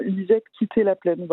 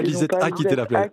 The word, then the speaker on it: Lisette quitté la plaine: On prononce bien, comme c'est écrit dans l'album, Lisette quitté la plaine, voilà, en créole haïtien. – Ah oui, pas Lisette a quitté la plaine.